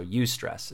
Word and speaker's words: eustress: There's distress, which we're all pretty familiar with that word, and then there's something called eustress, and it's spelled E-U and then stress. So eustress is how you pronounce it.